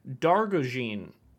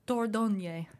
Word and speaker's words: Dordogne: explore the French countryside in Dargogne. Dargogne? How would you say that? – Dordogne. – Dordogne. I like that better.